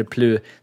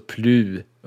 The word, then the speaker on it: Plö: Jag har nickat Plö, eller Plu, sedan jag var 18 och kan noll franska, så uttalet är rätt valfritt där. Själv säger jag det med ett lite längre u-ljud, alltså Plu typ.